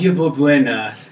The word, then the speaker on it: Yerba Buena: Yerba Buena.